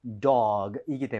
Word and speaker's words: dog: 자, 그리고 발음할 때 너무 duck 하고 끝내지 마세요. 이거는 dog이기 때문에 G로 끝납니다. 부드럽게 하셔야 돼요.